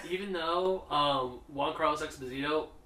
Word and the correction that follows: Giancarlo: Giancarlo Esposito. Wow. Mm-hmm. It's spelled Giancarlo.